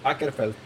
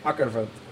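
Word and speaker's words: Åkerfeldt: Ackerfeld. – ¿Cómo? Ackerfeld. Él lo logró, y yo no. Este, lo acabo de descubrir esta mañana también, Ackerfeld.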